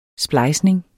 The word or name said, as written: splejsning